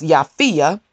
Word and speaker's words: Japhia: The fourth king or demonic spirit is, it looks like Japhia, but it's pronounced Japhia, and he is the king of Lachish, and I'm sure I didn't pronounce that right.